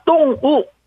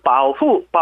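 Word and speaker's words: 똥우 (动物保护): 발음도 동물은 둘 다 사성입니다. 똥우, 똥우 하고 보호는요. 삼성, 사성, 바후, 바후 해서 동물보호 하면은 똥우, 바후, 똥우, 바후.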